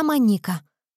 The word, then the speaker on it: Маника: Я Маника», — назвалась она, сделав ударение на втором слоге.